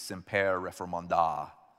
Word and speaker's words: Semper Reformanda: Today, we continue in a new series, Semper Reformanda, or as my friend and professor corrected my pronunciation last week, semper reformanda. Okay, you got to put the right emphasis on the correct syllable. So semper reformanda.